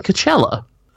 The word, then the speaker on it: Coachella: Wait, do you mean Coachella? – Probably.